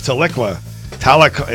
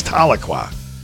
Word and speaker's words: Tahlequah: I thought it was Tahlequah. It sounds like Metallica, but it's Tahlequah. But anyhow, so there you go.